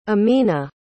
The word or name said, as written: amena